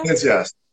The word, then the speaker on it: Venezia: Estou falando certo? Leone de Venezia? Venezia. – Venezia, certíssimo.